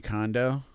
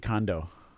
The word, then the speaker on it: Kondo: But I think it's more like Marie Kondo. Kondo? Like, uh-huh. The way people pronounce it, it sounds like it's Marie Callender's sister.